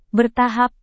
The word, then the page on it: bertahap: indonesian